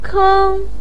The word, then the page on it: keng1: pinyin